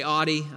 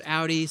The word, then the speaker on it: Audi: I worked for as a lot of people say Audi, I know, but I say this before and I'll say it again. It's pronounced Audi.